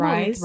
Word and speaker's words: Rhys: Pronounce Rise's name, but I kind of just went with Rise. – Rise. R-H-Y-S.